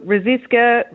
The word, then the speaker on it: Ruzsicska: Yeah, look, Ruziska, Ruz, however it comes out is perfectly fine.